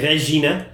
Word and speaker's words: Regina: Regina.